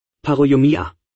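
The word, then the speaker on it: Paroiomia (παροιμία): Ich möchte die Betonung richtigstellen. Paroiomia.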